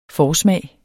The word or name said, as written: forsmag